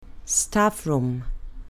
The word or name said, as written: staff room